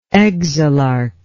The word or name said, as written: exilarch